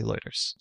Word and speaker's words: Lueders: It's spelled L-U-E-D-E-R-S, Eluders.